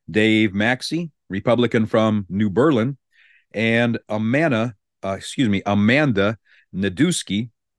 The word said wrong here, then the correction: Nedweski: Now, I pronounced that wrong. It's Nedweski, who is a Republican from Pleasant Prairie.